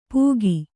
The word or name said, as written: ಪೂಗಿ